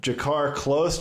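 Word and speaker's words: Drakkar Klose: Benil Dariush taking on Jakar Close. Do you like that pronunciation? Was that pretty good? – Yeah. Yeah, it's perfect. He's also American, so I'd hope you could get that guy's name right.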